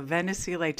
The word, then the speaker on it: Venesy: And just to clarify my last name, it rhymes with Tennessee, even though I'm not from there. So Venesy Tennessee.